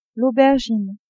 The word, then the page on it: aubergine: female